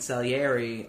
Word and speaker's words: Salieri: I'd say what resonates for me with Scaliari. When I look at Scaliari and I see... – Salieri. – Salieri.